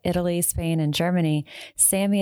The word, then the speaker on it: SAMe: So the first one is pronounced SAMI, and it's spelled capital S, capital A, capital M, lowercase e.